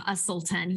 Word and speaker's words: Uselton: Well, we are, unfortunately, have a difficult last name, Usselton, U-S-E-L-T-O-N.